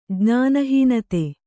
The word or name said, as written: ಜ್ಞಾನಹೀನತೆ